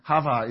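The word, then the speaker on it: Hava (חוה): In Hebrew, Eve is pronounced Hara. It's not pronounced Eve at all. I suppose you can look at it and get those letters, but Hava is Eve.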